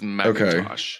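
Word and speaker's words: McIntosh: Pronounced the same way as Macintosh computers. – Oh, okay. – It's looking pretty unanimous, so it is Macintosh.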